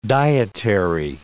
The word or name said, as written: dietary